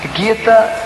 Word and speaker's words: Gita: Muita gente me pergunta o que é essa música, Guita, e se é Guita, se é Gita. Guita é a pronúncia porque vem de um livro indiano chamado Bhagavad Gita.